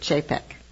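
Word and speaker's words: Čapek: You may have seen it spelled C-A-P-E-K, but he pronounced it Chapek.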